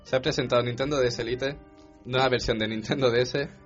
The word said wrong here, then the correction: Lite: Que bueno, que la de esto sería, la pronunciación sería DS Lite. – Sí, que ya, pero... vendría a ser como una bebida refrescante. – Like. – No, light.